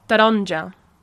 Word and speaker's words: taronja: Yeah, sometimes people miss out, so it's spelled T-A-R-O-N-J-A, but sometimes people miss out that A after the T, so they say taronja. Yeah, taronja. Taronja is technically how you should say it.